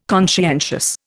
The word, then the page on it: conscientious: Indian